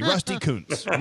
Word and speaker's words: Kuntz: Rusty Kuntz. It's spelled K-U-N-T-Z. Rusty Kuntz. Real or not? – No. – No, that's not true. Rusty played for the White Sox in the 80s. – Yeah. What? – Rusty Kuntz. – Not the way I would have pronounced it, but hey.